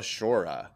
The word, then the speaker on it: Sciorra: That's probably Italian, and I should probably know how to pronounce it. I know. It's Sciorra. – Sciorra. – Yeah, I don't know if it's a ski or a she or what. Or she. – There we go. I found a pronunciation. – What we got? – Sciorra. – Okay. – Annabella Sciorra. – Okay. That makes sense. – And it is Italian.